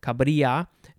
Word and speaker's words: Cabrilla: Next, we have Cabrilla. Cabrilla. C-A-B-R-I-L-L-A. Similar to Caballero with the double L. It's a y sound. Cabrilla.